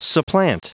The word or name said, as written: supplant